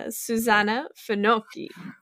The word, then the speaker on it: Susanna Finocchi: And we are here with a very dear friend of mine, Susanna Finocchi.